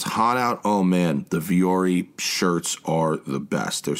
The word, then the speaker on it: Vuori: And it's pronounced Vuori, but it's spelled V-U-O-R-I.